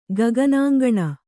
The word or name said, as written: ಗಗನಾಂಗಣ